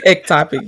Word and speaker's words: ectopic: You have two ectopic, did I say it right? – Ectopic, yeah.